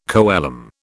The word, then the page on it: coelom: British English